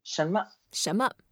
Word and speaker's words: 什么: 什么。什么. Do you pronounce the N or do you just kind of glaze over it? – We just make it light.